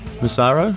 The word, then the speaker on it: Massaro: And welcome back to the second half of the show, brought in by the music of Ashley Massaro. Is that how you pronounce her last name? – Yes.